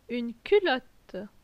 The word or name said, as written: Une culotte